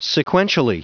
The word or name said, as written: sequentially